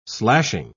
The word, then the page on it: slashing: British English